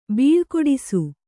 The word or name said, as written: ಬೀಳ್ಕೊಡಿಸು